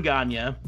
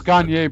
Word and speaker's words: Gagne: So for that final pay-per-view, he did the Wrestle Rock Rumble, which it's Gagne, by the way. – Yep, Vern Gagne. – Gagne. – You can say it Gagne. He pronounced it Gagne.